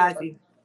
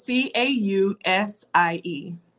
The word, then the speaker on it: Causie: Causie. Yeah, it's C A U S I E. Causie.